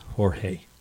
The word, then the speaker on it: Jorge: I think that should be pronounced Jorge.